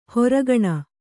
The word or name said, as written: ಹೊರಗಣ